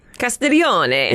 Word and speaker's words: Castiglione: Castiglione. – It's a C-A-S-T-I-G-L-I-O-N-E.